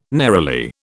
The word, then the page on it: neroli: British English